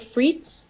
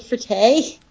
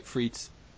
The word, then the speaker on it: frites: What is it? Steak frites? Steak frittee? – That's just steak and fries. – Yeah. How do you say that? – Yeah, you're right. Frites. – Okay, steak frites.